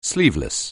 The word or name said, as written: sleeveless